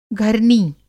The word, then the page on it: घरनी: Hindi